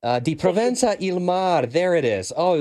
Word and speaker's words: Di Provenza il mar: Di Provenza il Mar.